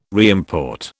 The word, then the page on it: reimport: British English